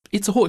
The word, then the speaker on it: Itzehoe: Itzehoe, natürlich, ja.